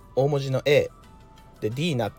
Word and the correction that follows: DeNA: って今言っちゃったけどあの DNA って読む読みますわ DNA ね